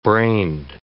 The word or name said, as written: brained